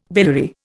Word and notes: biliary: Indian

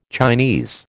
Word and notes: Chinese: American English

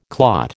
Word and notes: clot: American English

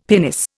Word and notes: pinnace: Indian

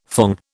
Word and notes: thong: British English

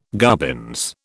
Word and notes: gubbins: British English

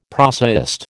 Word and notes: prosaist: American English

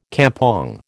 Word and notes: kampong: American English

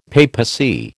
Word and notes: papacy: American English

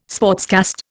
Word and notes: sportscast: Indian

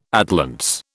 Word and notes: atlantes: British English

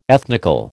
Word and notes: ethnical: American English

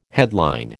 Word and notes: headline: American English

